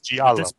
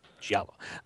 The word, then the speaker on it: Giallo: the Giallo films? Is that how you pronounce them? Would this be considered Giallo? – Giallo. – Giallo.